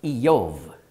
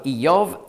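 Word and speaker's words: Iyov (איוב): Übrigens, Hiob spricht man hebräisch aus Iyov und Feind Ayev. Das ist im Hebräischen ganz ähnlich geschrieben. Also Iyov, Ayev.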